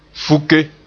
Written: fuke

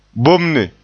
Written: bomne

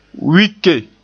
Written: wike

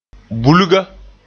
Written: bulga

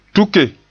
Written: tuke

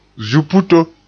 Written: zuputo

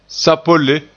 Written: sapolle

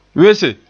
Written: wese

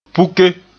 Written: puke

